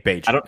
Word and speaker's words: Bagent: Tyson Bagent has looked good, and that's Bagent. It rhymes with agent.